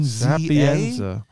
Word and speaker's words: Sapienza: Sapienza.